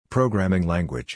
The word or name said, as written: Programming Language